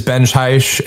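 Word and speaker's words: Benj Haisch: So my name is Benj Haish and that is spelled BenjHaisch.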